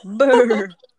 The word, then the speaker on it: Burr: You have to B-R. Say it like you're cold. Burr.